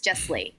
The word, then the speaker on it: Jesslee: So I like to tell people when you say it, it's like Leslie, but with a J. And so it's just JESSLEE.